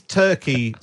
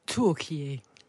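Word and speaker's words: Türkiye: So, is Turkey spelt the way that Rishi Sunak spelt it? Is that how, is it pronounced the same, or is it spelt, do we pronounce that differently? – Turkey.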